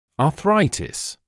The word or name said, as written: arthritis